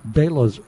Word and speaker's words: Bela: Best red wine of the Sonoma County Harvest Festival, the 2016 Buena Vista Bela's Selection Pinot Noir. I was going to say Bella, but Brian corrected me, so it's like Bela Lugosi.